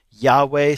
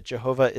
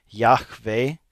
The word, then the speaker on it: Yahweh: It's Yahweh, sometimes pronounced Jehovah. If we're honest, I think we have to say that Jehovah is a mispronunciation of the name. It's actually probably pronounced something like Yahweh, which the difficulty in it is that in Hebrew, they didn't write vowels.